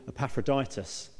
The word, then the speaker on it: Epaphroditus: We're looking at Timothy and a guy called Epaphroditus.